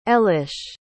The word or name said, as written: elish